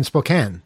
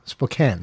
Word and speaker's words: Spokane: You hear the story, though, about the in Spokane. Did I say it right, Kyle? Spokane, right? – Yeah. – It's spelled Spokane, but you're not supposed to say it that way. You're supposed to say Spokane.